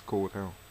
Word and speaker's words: Cordell: name is Paul, and my Christian name is Paul, and my surname is Cordell.